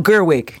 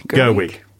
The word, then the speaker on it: Gerwig: No, Gerwig. – Gerwig.